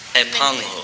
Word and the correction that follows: 太棒了: Right? You sound like too fat. – Too fat. I am too fat. Okay. – No. 太棒了。